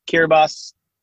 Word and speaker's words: Kiribati: Kiribati is how that country's name is spelled. – Okay. – But Kiribati, Sex Lives of Cannibals.